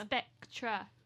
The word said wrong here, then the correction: Spectre: Spectre. – Spectrap. – Spectre. – That's how I say it, Marie. – Well, you're saying it wrong.